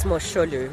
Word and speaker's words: Mosholu: Is that how you pronounce it? – Correct. It's Mosholu.